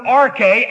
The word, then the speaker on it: arche: Put in English letters, A-R-C-H-E, pronounced arche.